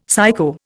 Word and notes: psycho: Indian

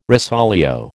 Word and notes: rosolio: American English

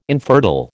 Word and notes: infertile: American English

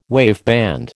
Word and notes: waveband: American English

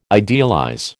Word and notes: idealize: American English

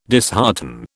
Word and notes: dishearten: British English

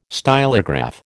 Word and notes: stylograph: American English

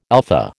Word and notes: alpha: American English

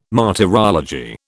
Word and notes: martyrology: British English

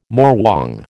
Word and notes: morwong: American English

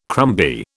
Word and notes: crumby: British English

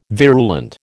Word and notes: virulent: American English

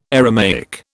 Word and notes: Aramaic: British English

